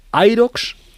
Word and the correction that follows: Hyrox: Irox cómo se dice Hyrox lo suelen llamar. – Hyrox.